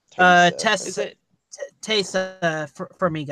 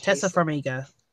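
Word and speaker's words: Taissa Farmiga: I honestly felt that way about and correct me if I say this wrong, I felt that way about, is it Taima? – Tessa Farmiga, I think. Tessa Farmiga.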